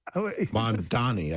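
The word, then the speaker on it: Mamdani: You mentioned the Mondavi Is it Mandavi? – Mandani, I think it is. – Mandani, thank you.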